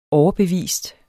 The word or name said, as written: overbevist